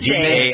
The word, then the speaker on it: dais: And she was happy to be on the dais. Is that how you pronounce it? – Yeah, that is correct. – The dais. D-A-I-S-E-S, a dais.